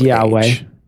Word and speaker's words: Yahweh: It's Yahweh.